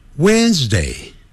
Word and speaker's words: Wednesday: Wednesday Wednesday When 那个 WD 那个 D 不念出来的 When 更不要念成 Wednesday、哦、不是按照那个字母这样来念啊不要自己发明叫 Wednesday 不是这样子 Wednesday Wednesday 有声的 z 啊 Wednesday Wednesday Wednesday Today 啊、礼拜四呢 Thursday 那个舌头先吐出来，然后很快往内缩。